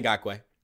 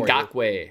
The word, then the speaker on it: Ngakoue: It's pronouncing Ngakoue. – Man, I thought I was being slick there. – No, I got you. It's all right. Just ask for help. I'm here for you. – Ngakoue.